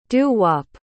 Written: doo-wop

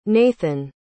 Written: nathan

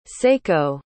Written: saeko

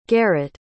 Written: garrett

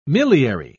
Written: miliary

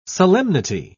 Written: solemnity

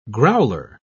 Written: growler

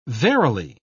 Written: verily

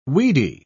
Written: weedy

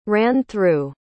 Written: ran-through

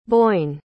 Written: Boyne

